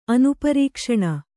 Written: ಅನುಪರೀಕ್ಷಣ

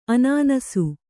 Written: ಅನಾನಸ

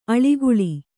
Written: ಆಳಿಗೊಳ್

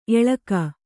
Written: ಏಳಕ್ಕಿ

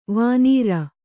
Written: ವಾಣರ

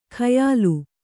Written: ಖಯಾಲು

